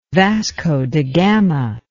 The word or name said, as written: Vasco da Gama